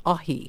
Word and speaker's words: Aji: Ahi. So it's spelt A-J-I, but it's pronounced Ahi.